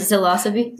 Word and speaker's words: Zilosophy: Zilosophy. – Yeah. Which is spelled like philosophy, but with a Z.